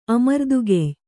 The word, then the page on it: ಅಮರ್ದುಗೆಯ್ಯ: amardugeyya